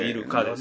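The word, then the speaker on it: 入鹿: 入るという字に鹿と書いてイルカですよね、イルカなのかね、これイルカと関係あるのかとかね、はい、昔そんなことを考えたんですけども、その後追記はしておりません。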